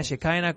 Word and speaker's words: Shekinah: Y eso es Shekinah, Shekinah, como quiera pronunciarlo, ve, es la misma cosa.